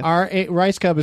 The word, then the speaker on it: Rajskub: Rice Cub is pronounced Rice Cub, but it's spelled RAJSKUB.